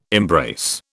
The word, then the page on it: embrace: British English